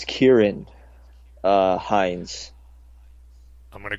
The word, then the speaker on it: Ciaran: I think he's, I think he's Irish, and I think they pronounce a C as a K. Uh, okay. – So Ciaran Hinds has been cast as Steppenwolf in Justice League.